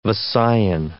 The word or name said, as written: Visayan